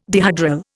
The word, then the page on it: dihedral: Indian